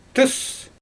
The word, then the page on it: tɯɯs: Xyzyl